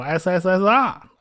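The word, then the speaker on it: SSSR (СССР): 呃，其实呢，这就是苏联的缩写，按照俄文发音呢，应该是念作 SSSR，跟那个发音差不多。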